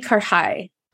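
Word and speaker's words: karahi: It's Pakistani karhai. – Karhai. All right. So like, everybody try karhai and don't be afraid to try goat. – And it could be pronounced karhai, like okay. with a D in there as well, just depending on, because, you know, India and Pakistan, there's so many different variations of the same dish, but that's the pronunciation.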